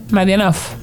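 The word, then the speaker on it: Madyanov: ¿Cómo se dice eso? Madianov. Uh-huh.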